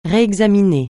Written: réexaminer

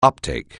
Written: uptake

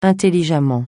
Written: intelligemment

